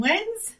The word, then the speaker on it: Wenz: So it's D-I-A-N-N-E, Diane's Vegan Kitchen. On Instagram, I am at Diane Wenz, D-I-A-N-N-E-W-E-N-Z.